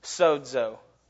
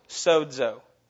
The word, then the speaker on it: sozo: I want y'all to say it out loud. Sozo. Sozo. Okay, it's spelled S-O-Z-O. Makes no sense. There's like a D in there that we can't see.